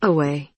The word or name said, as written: away